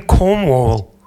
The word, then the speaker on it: Cornwall: Und dann fragte mich der Verkäufer, do you like Como? Ich so, excuse me? Do you like Como? Como? Italy? No, no, here, Como. Und ich so, oh, I'm sorry for my pronunciation. I mean Cornwall.